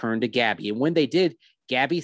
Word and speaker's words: Gabi: Gabby, it's spelled G-A-B-I.